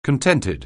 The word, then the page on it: contented: anglais